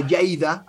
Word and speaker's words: Lleida: I went up to Les Borges in Lérida, or Lleida, as they pronounce it too.